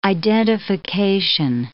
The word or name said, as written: identification